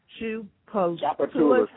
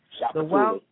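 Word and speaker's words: Tchoupitoulas: and please help me out with this word, the Wild Chapatulas. – How do you say it? – Wild Chapatulas.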